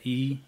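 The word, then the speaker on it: Yu (鱼): Yeah, yu. – It's like, why you, but it's... – But the way you pronounce it is... – It's in the back of your throat. Yu.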